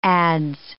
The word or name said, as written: adze